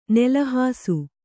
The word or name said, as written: ನಿಲಹಾಸ